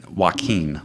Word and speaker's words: Joaquin: So this walking phoenix man, did I say his name right? Uh, Joaquin. Joaquin. Okay. Joaquin, not walking.